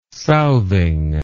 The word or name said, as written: southing